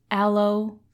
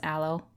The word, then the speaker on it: aloe: And when I tended your sting wound with aloe, I don't know how to pronounce aloe. Only read it. – You got it right the first time.